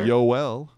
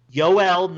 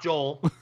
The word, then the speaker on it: Joel: And everybody who's listening, how do you say his first name? – Sir? Yoel. – Yoel, not Joel.